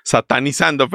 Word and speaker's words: satanizando: no, no, no, no quisiéramos, Rey, amigos míos, no quisieran no quisiéramos que se malinterpretara la situación de que eh, estamos satinizando, ¿cómo se dice? Satanizando, perdón, satanizando.